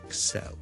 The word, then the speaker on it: excel: 个重音咧，其实咧佢系应该放喺 the second syllable 嘅，so excel。